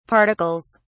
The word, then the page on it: particles: British English